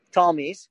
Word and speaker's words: Ptolemies: That the Egyptian Greeks, who were known as the Ptolemies, silent P, Ptolemies, and the Syrian Greeks, who were known as the Seleucids, were fighting over Palestine.